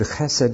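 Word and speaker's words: Chesed (חסד): In Hebrews, In Hebrew, C-H-E-S-E-D, Chesed. You pronounce the C-H like the Scots pronounce loch.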